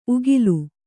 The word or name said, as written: ಉಕ್ಕಲು